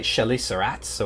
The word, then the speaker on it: chelicerates: Is it French like chelicerates, or is it chelicerates, or how do we pronounce that? Chelicerates. Chelicerates.